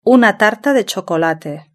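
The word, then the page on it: una tarta de chocolate: Spanish (Spain)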